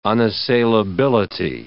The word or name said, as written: unassailability